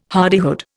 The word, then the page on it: hardihood: Indian